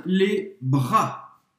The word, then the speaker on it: bras: Je m'étire les bras. Les bras. Je m'étire les bras. Você não vai pronunciar le S no final.